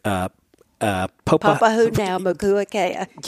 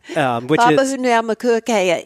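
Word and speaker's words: Papahānaumokuākea: Papahoutnaumokuakea. – Yeah, that's exactly it. – Papahoutnaumokuakea is a national marine monument that President Bush designated in the northwestern Hawaiian Islands.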